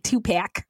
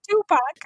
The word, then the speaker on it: Tupac: It's just funny. This was funny to hear a white lady say Tupac. It's Tupac. – Tupac. Okay.